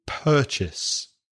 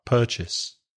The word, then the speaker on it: purchase: But the pronunciation wasn't correct. You stress the first syllable. So purchase. Make sure you stress the first part, purchase.